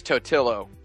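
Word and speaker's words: Totilo: And Stephen Totolo, Editor-in-Chief of Kotaku. – Whoa! – You mispronounced his name. – No, I didn't. – Yeah, you did. – Oh, you did. – You totally did. – It's Totillo.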